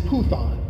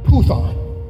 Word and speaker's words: Puthon: The Greek word for divination is it is Puthon. It's actually spelled like Python. It's pronounced in the Greek Puthon.